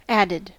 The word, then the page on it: added: American English